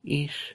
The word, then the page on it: ish: Lwaitel